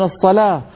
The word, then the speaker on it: الصلاة: لكن العرب كانوا يعربونها بألسنتهم، فالعرب يقولون الصلاة والزكاة، ولا يوجد عربي يقول الصلو أو الزكو، بل كلهم يقولون الصلاة والزكاة ولو كتبوها بالواو.